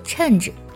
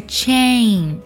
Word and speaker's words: chain: 那么我们在读这个单词的时候要特别注意，不要读作 change，不是陈，而是 chain，发 a 的音。